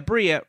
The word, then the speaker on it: Brea: Brea rhymes with diarrhea.